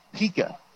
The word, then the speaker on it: Pika: That is Jeff Pika, pronounced P-E-E-K-A. It's spelled P-I-K-A.